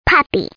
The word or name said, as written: puppy